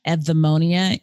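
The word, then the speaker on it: Eudemonia: Evdemonia, even though it sounds like EV, it's spelled EU,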